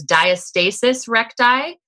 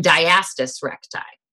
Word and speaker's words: diastasis recti: There's two different ways. So you'll hear two people say it different ways. So there's diastasis recti and diastasis recti. And they're both correct.